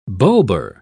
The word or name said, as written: bulbar